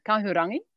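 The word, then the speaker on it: Kahurangi: Kaurangi? Kaurangi, hoe moet ik het uitspreken? ja, Kaurangi.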